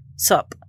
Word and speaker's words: Sup: Sup, I think that's how you say it. S-U-P, yeah.